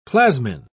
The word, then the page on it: plasmin: British English